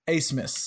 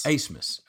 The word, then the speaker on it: Abmas: His name does not, it's not pronounced how it looks at all, which it throws me off every time. His name's pronounced Asmus. – Asmus, okay. – Which doesn't make sense because it's A-B-M-A-S, but I mean, hey, well, it's your name. You pronounce it how you want to.